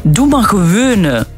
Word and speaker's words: Doe maar gewone: Doe maar gewone. – Doe maar, nee, niet gewoon, hè. De G, dat is moeilijk voor de rest.